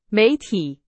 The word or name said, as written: 媒体